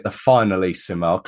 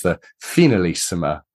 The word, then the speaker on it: Finalissima: But we'll start just by taking a look over the Brazil game, which was I was calling it the finalissima. Clearly, I got my pronunciation wrong. It's the finalissima. I think it's got that more European or South American accent to it, but it seems quite some time ago now, that game against Brazil.